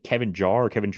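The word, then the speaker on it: Kevin Jarre: I didn't even know about, I think Kevin Jarrett is how you pronounce it.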